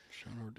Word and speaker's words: Shenandoah: Yeah, why don't you say it right? What's the boat? – The Shenandoah. – Yeah. Shenandoah. We're winding down for the season, but boy, we're booking for next season already.